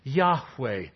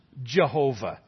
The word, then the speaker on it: Yahweh: We have learned to pronounce it that way because of the influence of the German language on our English language. But in the Hebrew alphabet, there is no J and there is no V. They couldn't have pronounced Jehovah.